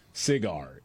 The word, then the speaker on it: SIGAR: SIGAR is how they say it.